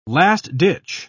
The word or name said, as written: last-ditch